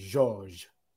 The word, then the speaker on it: Georges: I listened to the French pronunciation. It's Georges. Georges Niang.